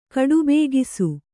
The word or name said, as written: ಕಡುಬೇಗಿಸು